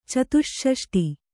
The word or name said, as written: ಚತುಷ್ಷಷ್ಟಿ